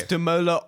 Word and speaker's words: Damola: It's Demola Otte. – D-A-M-O-L-A space O-T-E-G.